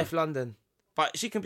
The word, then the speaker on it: London: Now, I want to say, I think it's Steph Lon-Don. Like, I'm a Don.